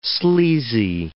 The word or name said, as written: sleazy